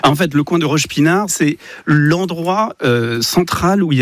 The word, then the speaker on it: Roche-Pinard: Vous changez de quartier, à Roche-Pinard. Alors, c'est ça, hein, c'est bien comme ça qu'on prononce. – Roche-Pinard.